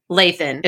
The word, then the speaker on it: Lathan: but I thought it was all going to be short A's. I was wrong. Lathan is her last name. I heard it from her own mouth.